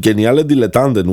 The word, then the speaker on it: Geniale Dilettanten: Yeah, you start your piece with, and this seems kind of strange to me, with Geniale Dilettanten, which is I hope I'm pronouncing this right.